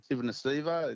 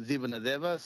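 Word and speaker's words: Petero Civoniceva: One of the all-time great Fijians and great players, Petro Sivanasiva. It's actually pronounced Zivanadeva. So when we're in Australia, we say Sivanasiva, but in Fiji, it's Zivanadeva.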